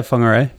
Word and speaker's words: Whangarei: And really the big highlight was when you were with me when we were in Whangarei. – Yeah, Whangarei. Yep. – Did I say that correctly? – Yeah, pretty close enough.